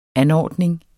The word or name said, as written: anordning